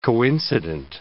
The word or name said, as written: coincident